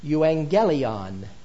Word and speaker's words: euangelion: When you come across the word gospel in Scripture, understand that you're looking at the Greek word pronounced euangelion, which simply means good news.